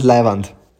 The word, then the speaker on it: leiwand: Lawand, richtig oder falsch? – Leiwand, meinst? – Ja. – Das ist Leiwand.